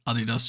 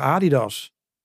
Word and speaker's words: Adidas: Ik zeg Adidas. – Adidas. ik zeg Nike en Adidas. Maar die Amerikanen zeggen Nike en Adidas. Dus hun leggen de klemtoon heel anders.